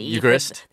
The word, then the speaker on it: Eucharist: Is it U-C-H? – It's E-U, actually. – Is it E-U? – It's E-U-C-H. – I had no idea. – So Eucharist, he was like U-K-A-R.